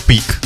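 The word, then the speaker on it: Peaq: Tak píše se to Peak, vyslovuje se to Peak, že jo? – Pak je Epic, to je Epic.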